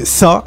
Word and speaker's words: SZA: Avec la chanteuse Sa. Alors je crois que ça dit S, ça s'écrit S-Z-A.